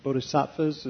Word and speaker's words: bodhisattvas: I mean, a multiplicity of Buddhas and bodhisattvas, is that how it's pronounced? – Uh-huh, yeah.